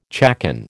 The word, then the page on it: chaconne: American English